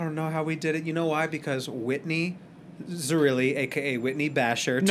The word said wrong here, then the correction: Bashor: Said that wrong. It's Whitney Bashor.